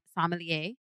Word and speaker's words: sommelier: Okay, but I didn't even know the role of a sommelier.